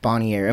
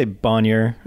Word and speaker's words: Bonnier: Yeah, you could say Bonnier. If you kind of say it quickly, you get away with it. You know, I don't know exactly how the family likes to pronounce, but we always just say Bonnier.